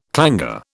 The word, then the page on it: clangour: British English